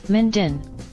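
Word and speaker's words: Minh Dinh: Minden.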